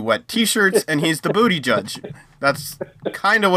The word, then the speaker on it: Buttigieg: I think it's Pete Buttigieg. And it sounds like a joke, but I'm pretty sure that's actually how it's pronounced. – Buttigieg.